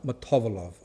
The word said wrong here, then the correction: Motovilov: I heard a Soviet someone who said pronounced it Motovilov. Oh no, I hope it's not right because I'm not used to that. No, Motovilov. Motovilov, is it? Now I've got to undo my... Anyway, Motovilov. Is that right?